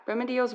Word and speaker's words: Remedios: Do you think that's pronounced Remedios? – Yeah. – Remedios Varo.